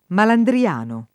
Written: Malandriano

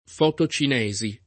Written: fotocinesi

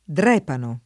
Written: Drepano

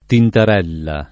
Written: tintarella

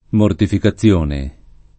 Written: mortificazione

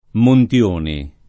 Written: Montioni

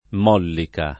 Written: Mollica

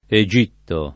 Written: Egitto

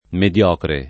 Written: mediocre

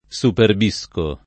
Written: superbire

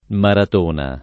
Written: Maratona

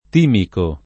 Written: timico

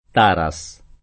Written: Taras

